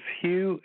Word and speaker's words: Hugh: My name is Hugh, H-U-G-H. The last name is Trawlson. It's spelled T like Tom, R-A-U-L-S-E-N like Nancy.